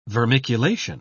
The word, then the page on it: vermiculation: British English